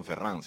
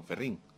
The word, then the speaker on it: Ferrín: Las hermanas Ferrín no son Ferrán, sino Ferrín. Sí, no, se pronuncia lo cubano, pero con mucho apellido francés que hay ahí.